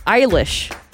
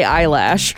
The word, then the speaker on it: Eilish: Jenny from Santa Rosa said Eilish. She said the singer Billie Eilish always say eyelash.